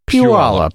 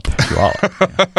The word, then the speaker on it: Puyallup: I think we need to do our listeners a solid and say it's pronounced Puyallup. – Puyallup. – Yeah. That's how it's pronounced.